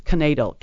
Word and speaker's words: knaidel: And by the way, it was broadcast live on ESPN last night by correctly spelling natal. And I don't know if I'm pronouncing that right. It's knadel, K-N-A-I-D-E-L, which is a kind of dumpling, and it's a German-derived Yiddish word.